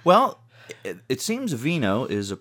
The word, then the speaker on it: wino: Although you're a wino, but it's W-I-N-O, but as we all know, in Polish, the W's are pronounced V's. So it is, in fact, Vino.